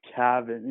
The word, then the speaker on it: Cavin: My name's Cavin.